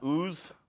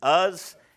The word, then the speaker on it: Uz: It says, there was a man in the land of Uz. It's Uz, not Ooz. Ooz would be O-O-Z. You know that. It's Uz.